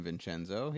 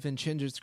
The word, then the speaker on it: Vincenzo: I've heard of the name Vincenzo, yeah. – Because it's in Italian when you pronounce the single C as a ch, right? – That looks right.